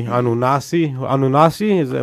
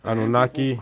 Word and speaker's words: Anunnaki: About the Anunnaki, Anunnasi? Am I saying it right? – Anunnaki.